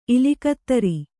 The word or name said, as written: ಇಲಿಕತ್ತರಿ